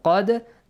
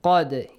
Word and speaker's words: قَدْ: قَدْ قَدْ قَصَصْنَا قَدْ دَال will be pronounced with an echoing sound. قَدْ, yes? – Yes.